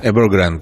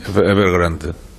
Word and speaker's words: Evergrande: Evergrande, porque es en inglés. – Okay. Sí, sin enfatizar la E final. – Sí, Evergrande.